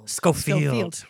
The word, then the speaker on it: Schofield: Schofield. But pretty close.